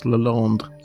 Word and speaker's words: Lalande: And it's created by a French astronomer called, I'm going to go, Jérôme Lalande. – I think you'll find it's pronounced Lalande.